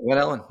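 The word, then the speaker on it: Glen Ellyn: Did I say that right? Glen Ellen? – Yep, Glen Ellen, yeah.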